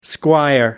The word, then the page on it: squire: American English